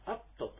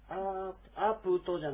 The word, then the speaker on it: アプト: 雨のことはアプトと言います。アプトですね。アープトじゃなくてアプトという、こういうアイズモのちょっと独特の発音になりますが、アプト、アプトアシナーとかといえば雨が降ったよ、降るよということですね。